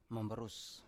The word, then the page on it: mambarus: Biak